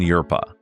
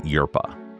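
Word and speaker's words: Hjerpe: The next witness was Jacqueline Yerpa. I believe I'm pronouncing that correctly. Her last name is spelled H-J-E-R-P-E. I believe it's Scandinavian and is pronounced, as I said, Yerpa.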